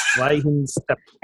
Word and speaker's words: Weihenstephan: Weihenstephan.